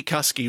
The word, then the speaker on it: Cusky: On the subject of our friends at WH Smith, Angie Cusky rhymes with husky.